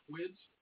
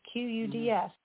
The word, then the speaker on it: Quds: Quids? – Were killed. What? How do you say that? – Quids? – Quids? I don't know. QUDS. There's no I. It's just QUDS.